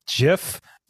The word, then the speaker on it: GIF: Upon accepting the award at the ceremony, Will Hite displayed a five-word slide that simply read in all caps, "It's pronounced GIF, not GIF." Here, GIF refers to the soft G pronunciation.